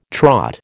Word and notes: Trot: American English

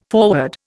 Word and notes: forward: Indian

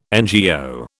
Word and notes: NGO: British English

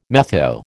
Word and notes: metho: American English

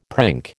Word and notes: prink: American English

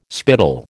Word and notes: spittle: American English

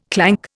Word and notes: clank: Indian

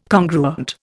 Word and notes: congruent: Indian

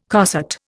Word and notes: coset: Indian

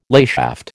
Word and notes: layshaft: American English

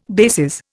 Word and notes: bases: Indian

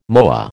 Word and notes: moa: American English

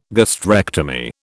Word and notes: gastrectomy: British English

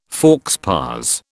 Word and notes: faux pas: British English

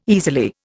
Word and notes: easily: Indian

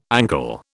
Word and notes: ankle: British English

